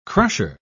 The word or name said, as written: crusher